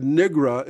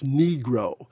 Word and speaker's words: negro: they said nigra in a way that sounded like something worse. And Coleman Young said, that's not the way you pronounce it. It's negro.